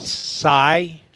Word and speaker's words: Tsai: How do I say this name? Joe what? – Tsai. – Joe Tsai.